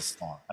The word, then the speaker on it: slog: It's a slag. It's not a slag. – That's not a slag. – It's a slog. A slog? – I don't think the British say. I think we say slog.